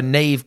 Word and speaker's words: Niamh: Like, so Neve Kelly. – Yes. – Neve is a big Neve Kelly, like, that was one of the players, and her name is spelled N-I-A-M-H. – Yeah. – Niamh.